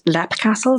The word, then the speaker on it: Leap Castle: And locally, it's pronounced Lep Castle, so it has the two pronunciations for it.